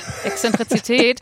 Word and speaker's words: Exzentrizität: Mit E richtig ausgesprochen hast. – Exzentrizität.